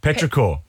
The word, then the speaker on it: petrichor: Petrichor. Yeah, petrichor.